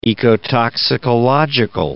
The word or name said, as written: ecotoxicological